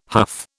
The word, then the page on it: huff: British English